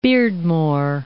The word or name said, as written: Beardmore